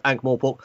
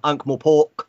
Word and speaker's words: Ankh-Morpork: Yeah, I looked it up yesterday, and I went to the man himself, Sir Terry, and found recordings of him discussing it and how he pronounces it. And since he created it, he knows how it's supposed to be. It is Ankh-Morpork. I've heard some really weird ones like Ankh-Morpork, Ankh-Mor, just really weird ways of mangling what's actually a pretty straightforward word.